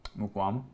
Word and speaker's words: Mukuamu: I thought it was Muakuomu. How do you say it? – Mukuamu.